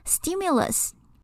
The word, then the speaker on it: stimulus: 这个单字在念的时候要稍微注意一下，它里面的那个 t 不是念 t，因为它位置在 s 后面的关系，所以它要念成 d 的音的。所以这个单字念 stimulus，那它的复数也比较特别一点，是 stimuli。